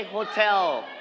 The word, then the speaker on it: hotel: Everybody say hotel. Everybody say it. Hotel. – Hotel. – Very good. All right. So we are going to straight on the second syllable.